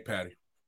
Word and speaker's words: Patti: Patty, am I saying his name right? P-A-T-T-I? – Yeah, Nick Patty.